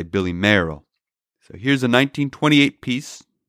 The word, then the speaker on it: Mayerl: Mayerl is spelled M-A-Y-E-R-L. So keep in mind, while I pronounce his name Mayerl, British people pronounce it Mail, because a British person would say, Billy Mayerl gave birth to a squirrel named Pearl, who was a girl, you know, something like that.